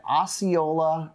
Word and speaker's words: Osceola: I'm Ryan Gresham, because we are on location in South Florida at Osceola Outdoors. I say it right, Mike? – Yes, you did.